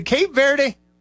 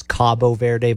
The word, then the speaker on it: Cabo Verde: You know, but the thing is it Cape Verde or Cabo Verde? – The right pronunciation is Cabo Verde, but they need to change there you go.